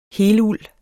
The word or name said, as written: heluld